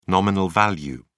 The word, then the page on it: nominal value: British English